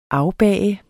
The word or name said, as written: afbag